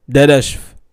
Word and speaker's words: Dadashev: Maxim Dadash. Maxim Dadashev.